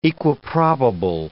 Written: equiprobable

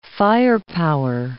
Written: firepower

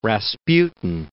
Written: Rasputin

